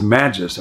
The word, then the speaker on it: magis: The Latin word is magis. I think it's pronounced like magisterial, but it's the word more.